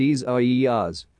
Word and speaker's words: Isaias: All eyes remain fixed as well on newly formed Tropical Storm Isaias, pronounced Isaias, which is currently south of Puerto Rico and moving to the Northwest.